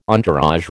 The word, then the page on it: entourage: American English